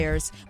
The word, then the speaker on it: Lyre's: Liars. But it's not spelled like L. How do you spell it? – It's spelled L-Y-R-E-S.